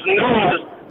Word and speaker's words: Nas: It's not Nas, it's pronounced Nas, and it comes from a king named King Nazir.